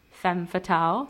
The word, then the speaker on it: femme fatale: Anyways, I don't know what I've been saying so far, but I just looked it up and it's femme fatale.